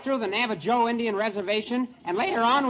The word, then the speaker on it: Navajo: In Spanish, you pronounce the J like an H. It's Navajo and La Junta.